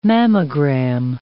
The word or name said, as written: mammogram